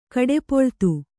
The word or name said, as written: ಕಡೆಪೊಳ್ತು